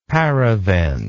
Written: paravent

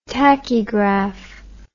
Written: tachygragh

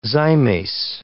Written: zymase

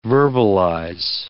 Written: verbalize

